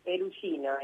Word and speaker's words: Perugino: Bueno, en primer lugar preguntarte si pronuncié bien tu apellido. – Perugino. Italiano, bien, Tano, muy bien.